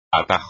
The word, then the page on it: atajo: espagnol